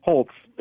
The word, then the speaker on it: Holtz: Is it with Holtz. It sounds like there's a T, but there's not.